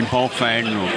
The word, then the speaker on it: Baofeng: The Baofeng, Watson. Am I pronouncing those right? – I think you got them both right. Usan, maybe. I'm not sure.